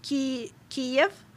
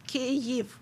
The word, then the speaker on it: Kyiv: because previously it was more common that people used Kiev. – Mm-hmm. – It's Russian. That's how you say, that's how you name Ukrainian capital in Russian, Kiev. Mm-hmm. But if you're speaking in Ukrainian language, it is Kyiv, which may be difficult to pronounce. I get it. You don't have such sounds.